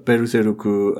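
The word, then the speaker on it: Beruzeruku (ベルセルク): and we get the Beruzeruku Adidas Furigana to indicate that's how it should be pronounced.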